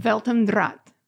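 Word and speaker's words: Welt am Draht: Can you, what's the German pronunciation? – Weltendraht.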